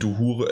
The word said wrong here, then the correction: Uhura: äh, Uhura äh, an und so weiter.